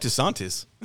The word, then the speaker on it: DeSantis: I like DeSantis. – DeSantis. – Just say it. Just say it. – Hello. I'm DeSantis.